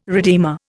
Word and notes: redeemer: Indian

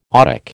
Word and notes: otic: American English

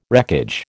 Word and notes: wreckage: American English

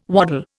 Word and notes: waddle: Indian

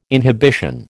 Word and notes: inhibition: American English

